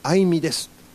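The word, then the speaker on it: 愛海: 兵庫県に住むあいみです。愛するの愛に海と書いてあいみさん、いいお名前ですね。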